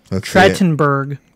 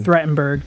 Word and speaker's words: Trachtenberg: Dan Trachtenberg. – Trachtenberg. – Trachtenberg, thank you.